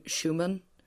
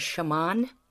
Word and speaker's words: Schamaun: I get Abigolia a lot. Abigolia Schumann. And it's I'm Abigolia Shaman, like what Michael Jackson used to say, Shaman.